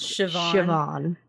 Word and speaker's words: Siobhan: Have you ever seen the name Siobhan? – Oh yeah, it looks like it's spelled all kind of crazy. – It looks like Siobhan. – Yeah, but it's pronounced Siobhan.